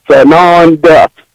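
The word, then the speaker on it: Tsananda: Just please make sure you add the T on the front. It is silent. Sananda.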